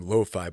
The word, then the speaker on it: LoPhi: So I go by the artist name Lo-Fi, but it's spelled L-O-P-H-I. It's my tongue in cheek. I'm always this kind of guy. Wordplay is my thing, but my name Phil is in that.